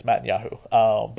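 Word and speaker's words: Matisyahu: Mattis Yahu. Sorry, that's how you pronounce it. It's Mattis Yahu.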